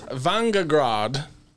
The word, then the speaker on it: Vingegaard: Tour de France leader Jonas Vingegaard.